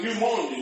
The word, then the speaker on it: pneumonia: It starts with a P, but it's like pneumonia. Pneumonia starts with a P, but you don't pronounce the P.